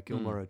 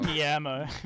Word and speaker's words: Guillermo: Yeah, Gilmore did a few of them. – Gilmore. – Yeah, something about that. – Gilmore. – My pal. What's his, how do you pronounce his name? – Guillermo.